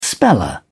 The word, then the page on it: speller: British English